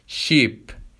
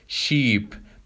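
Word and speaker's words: sheep: خب در رابطه با تلفظ دو تا حرف ای کنار همدیگه که بهش میگیم دابل ای، یعنی دو تا ای، تلفظ این دو تا حرف کنار همدیگه ای کشیده هست. ای کشیده هست. به عنوان مثال شیپ، شیپ. منتها شیپ که با آی هست به معنای کشتی، یک چیزی بین ای کشیده و ای کوتاه هست. اما اگر با دوتا ای باشه شیپ به معنای گوسفند هست.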